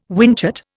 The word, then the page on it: whinchat: Indian